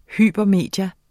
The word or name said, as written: hypermedie